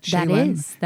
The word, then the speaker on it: Shaylen: Shaylen. That is Shaylen. How you say it.